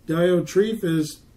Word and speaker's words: Diotrephes: I wrote unto the church, but Diotrephes, I think that's how you say his name. – Yeah. – Diotrephes, who loveth have the preeminent.